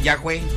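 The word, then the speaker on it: Yagüe: Estoy diciendo bien el apellido, ¿verdad? – Sí, Yacué. – Yacué. – Ahí fue. – ¿Con G o con Q? Yacué. Ah. O sea, se escribe con G y se lee con Q, Piroberta.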